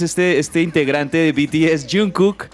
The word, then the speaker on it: Jungkook: Estoy hablando de Jungkook, así se pronuncia.